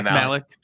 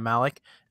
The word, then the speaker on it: Malek: Rami Malek. – Yeah, Malek.